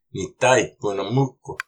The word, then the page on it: Nitay ponamoq!: Algonquian